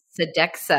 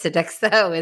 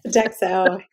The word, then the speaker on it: Sodexo: I also realize that I pronounced your company incorrectly. I think I said Sodexo, and it's Sodexo, isn't it? – Sodexo, yes.